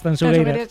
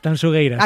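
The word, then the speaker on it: Tanxugueiras: A ver, repítemelo, como tengo que pronunciarlo bien. – Tanzugueiras. – Sí, esto me lo corrige Fermín Agustín mucho. Marta, por favor, digo, mira que yo siempre pronuncio... A ver, otra vez, dímelo. – Tanzugueiras. – Acaba de deshacerse la manifestación a las puertas de la Gran Vía, de lo bien que lo has dicho.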